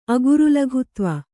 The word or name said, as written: ಅಗುರುಲಘುತ್ವ